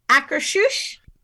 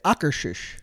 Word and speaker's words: Akershus: I have been taught that it is Akershus.